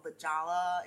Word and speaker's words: La Jolla: So she was looking up L-A-H-O-Y-A. She's like, I didn't know it's spelled La Jolla.